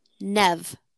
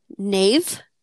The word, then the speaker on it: Neve: How would you pronounce it? – Nave? – Interesting. – Or like Neve, maybe? Because it's spelled like N-E-V-E, correct? – Yeah. I've always heard it Nev Campbell. – Okay. – It's definitely not Nave. It might be Neve.